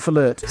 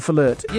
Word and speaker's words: Pfahlert: John Fahlert. – So it doesn't sound that complicated, except it's spelt P-F-A-H-L-E-R-T. – John Fahlert.